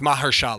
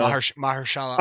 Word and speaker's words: Mahershala: Ali Mahushala. Am I saying that right? That's who they talked about for Joel. Mahushala Ali. – Oh yeah, I did see that. Yeah. – Am I saying that right? It's Mahershala.